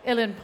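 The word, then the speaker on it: Uilleann: First of all, did I pronounce the Ilan Pipes correctly? – You got that one right.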